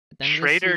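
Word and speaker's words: Schröder: Schrader, dude. Schrader. Respect. – I can't say it right. I know you know the German stuff better than me. And I took my time trying to figure out if I could actually say it right, and I still didn't. So I just think it's funny. He's the first player in NBA history to get an umlaut.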